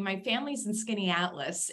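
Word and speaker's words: Skaneateles: I like that you just pronounced Skinny Atlas for me because I pass that sign all the time and I have no idea how to pronounce it. So Skinny Atlas, that's great.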